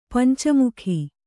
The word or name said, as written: ಪಂಚಮುಖಿ